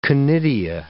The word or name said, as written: conidia